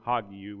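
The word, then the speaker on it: hagiou (ἁγίου): It's a rough breathing mark. You add the H sound, hagiou would be the correct pronunciation.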